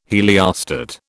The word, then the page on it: heliostat: British English